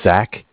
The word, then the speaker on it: Zach: So my name is Zach, spelled Z-A-C-H. – Mm-hmm. – I'm calling from Bank of Cardiff. Cardiff is spelled C-A-R-D-I-F-F, like Frank.